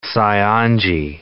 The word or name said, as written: Saionji